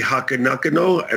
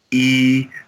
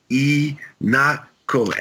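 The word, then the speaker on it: Ihnatko: Okay, so for instance, I say, Siri, call Andy Inatko. And she goes, call Andy Hakanakano. And I'm like, that's not how you say it. She goes, well, how should I say it? And you go, E-not-ko. And she goes, Inakinakino. And I go, no, one more time. E-not-ko.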